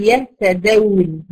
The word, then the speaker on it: يهتدون: لاحظ ان انا مش بقول يهتدون ما بنطقش واو، لكن بمد بيها الدال اللي قبلها في كلمة يهتدون مثلا.